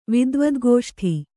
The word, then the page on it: ವಿದ್ವದ್ಗೋಷ್ಠಿ: kannada